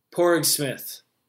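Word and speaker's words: Padraig Smith: Porig Smith. That's how you pronounce it.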